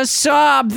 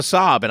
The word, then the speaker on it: Saab: It's not SOB. – Yeah, but you... – I'm driving an SOB. – But people say I drive a Saab, and I always feel like I feel sorry for you.